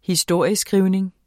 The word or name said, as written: historieskrivning